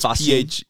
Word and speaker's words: phosgene: I might have been saying that wrong. Something, it's phosgene. Phosgene. Yeah. There you go. That's probably what it is.